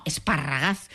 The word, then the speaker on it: esparragas: Las espinacas esparragas, que dicen ellos. Yo dije esparragadas, ¿no? Se escriben. No, esparragas.